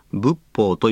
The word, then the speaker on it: 仏法: 仏法とは仏様の仏と法律の法と書き仏法と読みます。